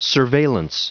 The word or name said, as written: surveillance